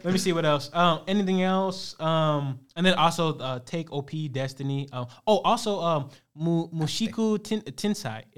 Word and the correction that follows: Mushoku Tensei: It's Mushoku Tensei. I think that's how you pronounce it.